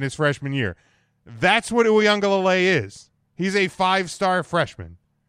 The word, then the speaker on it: Uiagalelei: Like, Uiagalelei is not – he's a 5-star recruit.